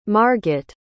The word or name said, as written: marget